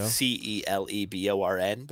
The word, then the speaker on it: Celeborn: Lord of the Rings has Celeborn, whose name is spelled Celeborn, but it's a hard C, so it's the Kel.